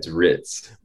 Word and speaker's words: Ritz: You almost got it. It's Ritz. – Like Ritz. – Like the hotel.